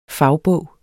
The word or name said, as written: fagbog